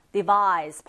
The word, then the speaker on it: device: device에서 끝에 se로 끝났을 때와 ce로 끝났을 때가 품사가 다릅니다. device 발음도 다른데요.